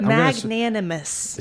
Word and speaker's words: magnanimous: Magnanimous. – In the middle, it's an N-A-N. Magnanimous.